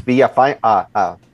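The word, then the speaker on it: Villafaña: it's Villafana. The N is silent.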